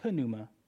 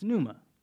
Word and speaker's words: pneuma: Yeah, I know it looks like pneuma. It's okay. But it's pneuma. Somehow, some way, the P is silent in Greek.